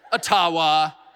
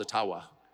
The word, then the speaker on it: Ottawa: And of course, it was intentional until I received a very sincere email from an Ojibwa First Nations leader who said, actually, it's called Ottawa. And they've been mispronouncing it all these years. I'm not making that up. And if you have any Ojibwa friends, ask them. It's Ottawa.